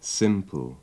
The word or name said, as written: simple